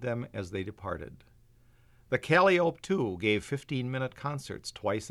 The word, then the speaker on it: calliope: No steamboat on the upper Mississippi River ever had a calliope. On the river, it was always pronounced calliope.